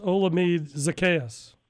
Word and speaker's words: Olamide Zaccheaus: How do you pronounce his name? – That's Olamide Zaccheaus.